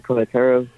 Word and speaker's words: Cohetero: And Dayron, I'm sorry, pronounce your last name for me. – Coetero, C-O-H-E-T-E-R-O.